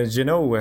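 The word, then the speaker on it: Jeannot: Eh, exakt, det är Gino. Eh, hoppas att vi sätter uttalet där.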